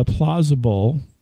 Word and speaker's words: Plauzzable: We're going to talk just a little bit uh, about other things, but I think I'm going to touch upon uh, Plauzzable.